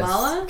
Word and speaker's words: Kamala: You're like Camila Harris. – Kamala. – Sorry, yes, I mispronounced it. Sorry. – Or Kamala, actually. – Kamala. That's like Caribbean or caramel.